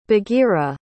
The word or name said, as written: bagheera